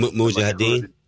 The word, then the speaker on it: Mujahideen: M- Mujahideen.